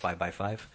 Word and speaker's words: VXV: They had an album called, they spelled it VXV, but it's pronounced 5x5.